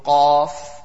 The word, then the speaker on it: qaf: Last week we had discussed the difference between the qaf and the kaf. So kaf was easy recited, free-flowing kaf as compared to qaf, and that would is pronounced with a full mouth.